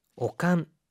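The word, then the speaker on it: 悪寒: 오칸. 세기가 되다리 오칸가 する時は 오프로니 하이라나이데 くださ이. – 자, 오한이라고 할 때 이 악자는요. 오로 읽어서 오칸이라고 합니다. 세기가 되다리 오칸가 する時は 세기가 되려 기침이 나거나 오칸가 する 오한이 들 때는 오프로니 하이라나이데 くださ이.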